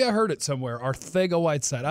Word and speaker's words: Arcega-Whiteside: And but did you just drop in a perfect pronunciation of Arcega-Whiteside? Instead Yeah, J.J. Arcega-Whiteside.